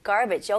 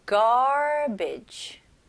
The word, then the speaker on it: garbage: 그래서 첫 음절은 gar. car 할때 gar. 그 다음에 두 번째 음절은 bitch. bitch. garbage. garbage. 네. 강세는 첫 음절을 주시면 훨씬 더 원어민스럽게 발음하실 수 있어요.